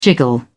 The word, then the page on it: jiggle: British English